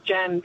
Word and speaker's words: Jan: So I've never liked the shortened version of my name, Jan, because Australians make it into some sort of three-syllable word, and I don't know who Jan is, but it's not me.